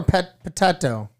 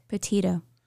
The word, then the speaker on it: Petito: Is it Petito or Pet Potato? – Petito. – Petito case. I just don't want to say it wrong because I know it's sensitive.